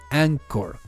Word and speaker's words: Anchor: Anchor se escribe A-N-C-R-O-R. r o r Anchor. Es como Anchor, ¿no? Sería en español, pero se dice Anchor.